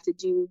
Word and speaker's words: Teux Deux: Another personal habit that's helped me significantly, to-do lists. So I actually use an app. It's called To-Do, but it's spelled T-E-U-X. D-E-U-X.